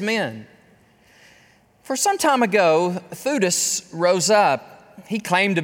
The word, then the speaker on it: Theudas: One named Theudas, just happened to rhyme with the man named Judas of Galilee, rose up in the days of the census.